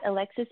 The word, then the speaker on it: Elexis: Yeah, um, I'm on Instagram at Elexis Ansley.